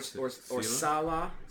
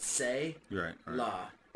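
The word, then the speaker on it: say la (צלע): Selah, yeah, T-S-E-L-A. – T-S-E-L-A is how it would be spelled, but the phonetic, that would be pronounced say la. Or it can be pronounced sa-la.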